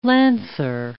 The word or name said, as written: lancer